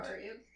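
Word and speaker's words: Cairhien: kind of at the intersection of Carheen, Tyr, and Carian. – Yeah, Carian, whatever, Tyr and Andor.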